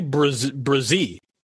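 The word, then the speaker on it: Bresee: By the way, I pulled up the beast, and it's actually Brzee, Brian Brzee, which I've been pronouncing wrong all off season.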